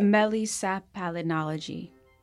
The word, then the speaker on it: melissopalynology: What is correct? – Melissopalynology. So it's Melissopalynology. – Exactly.